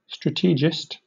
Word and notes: strategist: British English

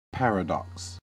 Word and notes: paradox: American English

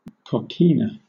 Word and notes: coquina: British English

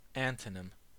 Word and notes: antonym: American English

